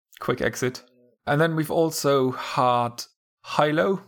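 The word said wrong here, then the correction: Hailo: I think it's pronounced that way. – Halo. Hailing a taxi.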